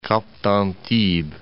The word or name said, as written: Cap d'Antibes